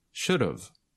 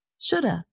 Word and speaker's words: should've: But native speakers usually shorten these with contractions, so it sounds like this. Should've, could've, would've. – And sometimes native speakers reduce or shorten the pronunciation even more. So it sounds like this. Shoulda, coulda, woulda.